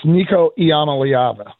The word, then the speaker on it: Iamaleava: I keep saying Amalevin, but how do you pronounce his name? You probably know. – It's Nico Iamaleava.